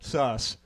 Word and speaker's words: Szasz: Szasz.